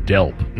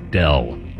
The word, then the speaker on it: Delp: It's Lauren Delp, not Dell. D-E-L-P.